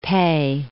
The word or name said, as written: PEI